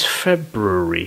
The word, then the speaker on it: February: It is February. – February. – It's like Feb. Brewery.